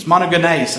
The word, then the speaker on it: Monogenes: Monogenes is how it's pronounced.